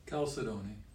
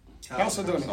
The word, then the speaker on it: chalcedony: Chalcedony. – Chalcedony. Oh, wow, you made that sound easy. Chalcedony.